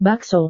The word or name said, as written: bakso